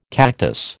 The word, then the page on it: cactus: American English